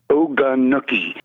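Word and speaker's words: Oganookie: It's O-G-A-N-O-O-K-I-E, Ogunookie.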